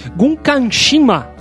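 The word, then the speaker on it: Gunkanjima: Como é que se pronuncia isso daqui que eu não sei? Gunkanshima. é isso aí, cara.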